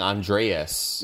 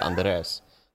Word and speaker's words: Andres: It'd be white people saying Andreas, or Andreas, like San Andreas, and it's like there's no A there, it's just Andres.